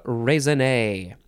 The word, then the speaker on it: raisonné: Okay, next we have catalogue raisonné. This has got to be French, right? Catalogue raisonné, two words. Raisonné is spelled R-A-I-S-O-N. N-E, and there's an accent over the E at the end.